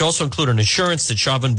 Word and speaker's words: Chauvin: If convicted of second degree murder, Chauvin, the police officer, I sound like I'm, I, when you say his name, it sounds like it's a Boston accent. Chauvin. It's the store to him. Chauvin would likely face, that's just the way it's pronounced, Chauvin would likely face about 11 to 15 years in prison.